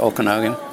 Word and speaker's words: Okanagan: Parker Ranch shipping there, am I pronouncing the valley correct? Okanagan? – Yes.